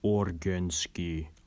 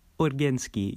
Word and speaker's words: Organsky: So, you're Soviet, that correct, Gorginsky? – It is pronounced Organsky, like organ. – Now, Organsky, you're my hero.